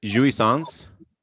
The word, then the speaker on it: jouissance: But first of all, so is it jouissance? – Yeah, I believe you said it correct.